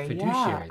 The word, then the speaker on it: fiduciary: For example, fiduciary. Have I said it right? – Fiduciary, yeah.